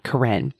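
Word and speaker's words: Koren: And for a long time, I would just let people mispronounce it, call me Karen. It's spelled like Karen, but with an O. So it's K-O-R-E-N.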